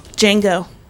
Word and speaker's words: Django: Django. – Okay. The D is silent.